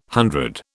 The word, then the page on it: hundred: British English